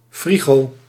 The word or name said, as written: refrigerator